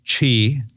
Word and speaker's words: Qi: And the predominant standard, if you will, for that is pronounced Qi. It's spelled Q-I.